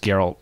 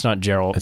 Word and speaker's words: Geralt: It took me a while, but I remember it as Geralt. – Oh yeah, it's a hard G. It's not Geralt.